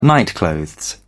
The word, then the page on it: nightclothes: British English